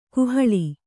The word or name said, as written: ಕೋಹಾಳೆ